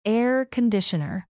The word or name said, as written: air conditioner